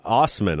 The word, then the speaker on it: Assman: Are you sure this is how you say that? – Yeah, Osman. I'm positive that's how we're saying it. – Okay. Yeah, we'll say that. Osman guy here. – It's spelled A-S-S-M-A-N, Osman.